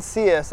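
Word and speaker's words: Sias: Hernan Cias. That's H-E-R-N-A-N-S-I-A-S.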